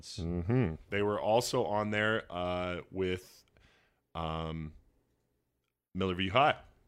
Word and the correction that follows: Millview High: Or, no, I'm saying that wrong. Millview High? – Millview High, I think. – Yeah, I think that's right.